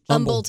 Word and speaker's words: Humble: It is a silent H, which is silly. Thank you. – Yes. – What are you talking about, silent H? – Humble, Texas.